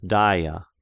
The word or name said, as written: Daia